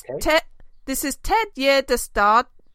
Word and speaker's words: Ted Gärdestad: This is Ted Gärdestad.